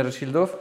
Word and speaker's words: Rothschild: z rodziny Rothschildów? – No. – Tak? Dobrze powiedziałem? – Dobre pytanie, bo faktycznie w Polsce wymawia się Rothschildowie, z tego co nauczyłem się ostatnio, a po angielsku powiedzielibyśmy Rothschilds.